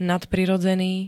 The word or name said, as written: nadprirodzený